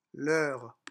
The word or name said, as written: leur